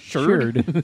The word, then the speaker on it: Xrd: The X is pronounced as a sh. – Shard. – Shard. – Guilty Gear Shard.